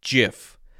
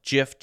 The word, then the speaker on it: GIF: I have always pronounced this as GIF, the soft G sound, also known as the J sound. GIF, GIF, GIF.